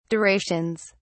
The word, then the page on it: durations: British English